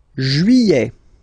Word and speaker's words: juillet: Juillet. Okay, you get this double L here. Y-y-y-y-y. Juillet. Juillet. Don't pronounce this final T, E-T here at the end.